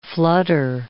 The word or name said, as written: flutter